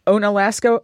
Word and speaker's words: Onalaska: And Frank, what about you from Onalaska, Wisconsin? Am I pronouncing it properly? – That's close enough.